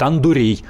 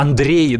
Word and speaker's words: Андрей: японцы называют Андурей. А, Андрей, Андрей. ДР он не скажет, Андурей говорит.